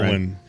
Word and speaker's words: Alwyn: Alwyn.